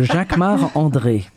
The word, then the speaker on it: Jacquemart-André: So Jacques-Marc André.